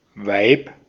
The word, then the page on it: vaimo: /ˈʋɑi.mo/; /ˈvɑimo/